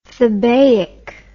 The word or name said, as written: thebaic